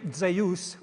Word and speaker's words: Zeus: but Greek Zeus, where the dy has turned into a dz, and now we pronounce it z, but it was dz in ancient Greek, Zeus.